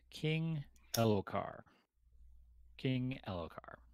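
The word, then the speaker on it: Elhokar: And yeah, he has said King Elokar, the H is silent.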